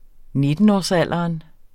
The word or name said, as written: nittenårsalderen